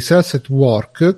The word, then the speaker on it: Cells at Work: Sales at Work Uh, vedo se l'ho detto bene. Sì, Cells at Work, che è quell'anime che è uscito di recente sul.